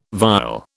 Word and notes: vial: British English

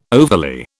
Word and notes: overly: British English

